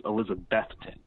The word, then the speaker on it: Elizabethton: I'd always said Elizabethton when pronouncing the name of the Twins, just kind of Elizabethton. But I've gotten corrected a few times with Elizabethton. You really, when you start to say that word, think of Beth, the name Beth.